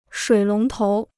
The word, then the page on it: 水龙头: shuǐ lóng tóu